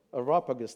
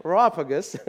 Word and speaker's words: Areopagus: How do you say it? Areopagus, thank you. That's how they say it in English. Okay, Areopagus.